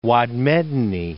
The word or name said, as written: Wad Medani